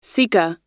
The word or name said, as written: sika